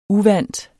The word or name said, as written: uvant